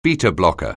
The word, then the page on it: beta-blocker: anglais